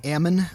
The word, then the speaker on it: Ammon: It's actually Clive and Bundy's son, is it Ammon or Amon? – Ammon, I think is how they pronounce it, yeah.